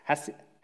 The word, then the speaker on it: hesed: She uses the Hebrew word, it's not in the English, but it's translated from the word hesed. I'm probably saying that wrong. Hesed? What do you think, Glenn? Hesed? What do you think, Ian? Hesed? Okay, good. Got the thumbs up.